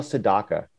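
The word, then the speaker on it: Sedaka: Oh, Neil Sadaka. That's how you say his name.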